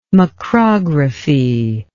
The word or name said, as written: macrography